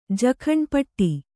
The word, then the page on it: ಪುಟ್ಟಿ: Kannada